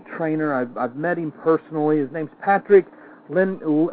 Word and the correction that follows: Lencioni: I never get his name right. Lencioni, Lencioni.